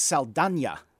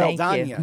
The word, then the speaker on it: Saldana: And by the way, it's Saldana. – Yes, well, Saldana. So let's be sure we get that right. – Saldana.